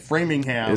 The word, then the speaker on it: Framingham: That's how you say it, Framingham.